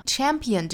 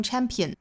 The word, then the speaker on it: champion: champion 这个词还要注意啊它是一个三个音节的词所以不要读成 champion 应该是 champion 所以是三个音节大家数清楚再读啊哒哒哒 champion 后面加的这个 s 应该读浊音所以是 champions